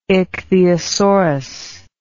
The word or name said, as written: ichthyosaurus